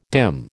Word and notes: dim: American English

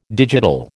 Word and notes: digital: American English

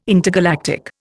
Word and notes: intergalactic: Indian

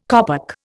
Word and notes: kopek: Indian

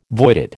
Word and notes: voided: American English

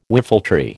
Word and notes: whiffletree: American English

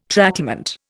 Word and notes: tracklement: Indian

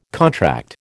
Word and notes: contract: American English

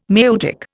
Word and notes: maieutic: Indian

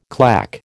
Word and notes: clack: American English

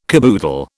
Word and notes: caboodle: British English

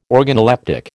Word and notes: organoleptic: American English

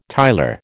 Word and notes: tiler: American English

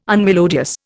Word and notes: unmelodious: Indian

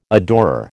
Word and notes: adorer: American English

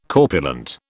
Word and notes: corpulent: British English

